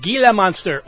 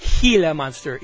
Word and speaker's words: Gila Monster: Was King Gizzard and the Wizard Lizard with Gila Monster, or Gila Monster, if you want to pronounce it correctly.